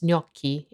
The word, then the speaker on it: gnocchi: Most English speakers pronounce gnocchi in one of two ways, gnocchi in the UK or gnocchi in the US. Here's how you would pronounce it in Italian.